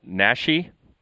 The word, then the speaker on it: Naschy: And he included, I think, a pretty obvious homage, I guess, longtime veteran of Spanish language horror, Paul Naschy. Is that how you yes. – pronounce it? – Yeah, he came up on Facebook today.